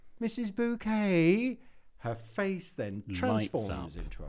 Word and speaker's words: Bucket: But Richard informs him that it's pronounced bouquet.